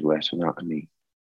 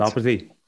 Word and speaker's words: retinopathy: And they said he was blind because of diabetic retinopathy. No, I didn't say that right. Retinopathy, I think it's called. – Retinopathy. – Retinopathy. – Yeah.